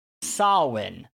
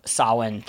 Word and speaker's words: Samhain: And we'll be focusing largely on, and here's where I'm glad that I looked up how to pronounce this, Samhain, or Samhain, which is spelled Samuin, or Samhain. So, sounds nothing like it looks.